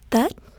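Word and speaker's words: Tết: It's actually pronounced Tet. It's spelled T-E-T.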